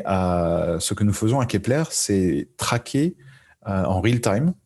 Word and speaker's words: Kepler: je vais juste corriger la prononciation. C'est Kepler. – Kepler, euh, d'accord.